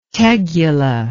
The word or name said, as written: tegula